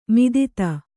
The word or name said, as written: ಮದದ್